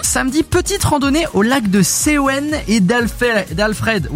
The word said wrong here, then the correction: Alfeld: Oula! – D'Alfeld, lac d'Alfeld.